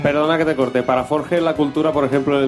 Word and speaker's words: Forges: Es Forge, ¿no? Se pronuncia. Forges.